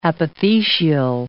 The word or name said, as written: apothecial